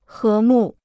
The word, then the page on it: 和睦: hé mù